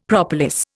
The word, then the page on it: propolis: Indian